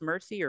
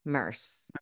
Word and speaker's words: Merce: As Sutton discusses what she will and will not pack, she reminds Avi that, is his name pronounced Mercy or Merce? – Merce.